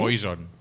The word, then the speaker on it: poison: Pero, ¿y cómo se pronuncia veneno entonces? ¿Cómo? – Poison. – Poison. Ah, es verdad, es verdad.